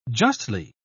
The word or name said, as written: justly